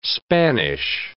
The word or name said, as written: Spanish